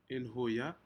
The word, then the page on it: inhuya: Aneityum